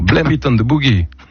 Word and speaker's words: Blame It on the Boogie: Et les Jackson 5, Laurent Romeshko, Blame It on the Boogie. Je l'ai bien prononcé. Hein. – Oui, c'est bien.